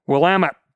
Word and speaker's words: Willamette: Willamette, damn it, as I've been told many times.